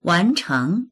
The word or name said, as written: wan2cheng2